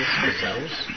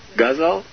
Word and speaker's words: ghazal: Gazelles? – Gazelle is a type of animal, like a buffalo, but... it's Hindi love songs. What? – Ghazal. – Like guzzling.